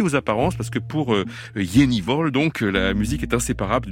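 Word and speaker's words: Jenny Hval: Et juste avant, c'était la Norvégienne, alors il paraît qu'on prononce son nom comme ça, quelque chose comme Jennival, voilà, ça s'écrit Jennival, H-V-A-L, dans High Ellis, c'est un extrait de son dernier album, intitulé The Practice of Love.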